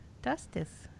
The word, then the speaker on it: Dustice: D-U-S-T-I-C-E. Dustis.